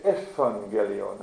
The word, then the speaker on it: Euangelion: Das Ü wird auch als V ausgesprochen, sodass wir Evangelion haben, Evangelium.